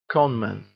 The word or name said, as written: con-man